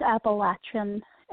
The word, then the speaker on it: Appalachian: So in this part of the region, in the Southern Highlands where I am in Western North Carolina, it's Appalachian, and we tell people it's pronounced. If you don't pronounce it this way, I'm going to throw an Appalachia, and that's how you remember how to pronounce it.